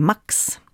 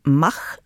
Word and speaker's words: Max: Jag har läst på lite grann om den ryska statliga meddelandetjänsten Max, eller Mach som man uttalar den i Ryssland om man är patriotisk. Då vill man inte kännas vid det västerländska uttalet Max.